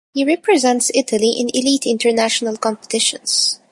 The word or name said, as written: competitions